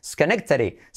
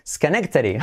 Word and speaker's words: Schenectady: Schenectady. Schenectady.